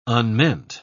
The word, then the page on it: unmeant: British English